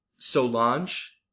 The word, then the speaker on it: Solange: And then Solange, which mm-hmm.